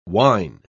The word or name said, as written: wine